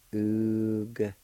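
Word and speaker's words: ugata: In this word, the A with the G will be silent, and you can pronounce the G sound with a U, like this. Oog-ta.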